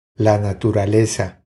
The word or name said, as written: La naturaleza